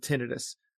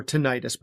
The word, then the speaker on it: tinnitus: So as the listeners know, I'm an otolaryngologist, and I see a lot of patients with tinnitus or tinnitus. Both pronunciations are actually correct.